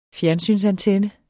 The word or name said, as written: fjernsynsantenne